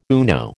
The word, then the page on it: UNO: American English